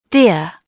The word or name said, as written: deer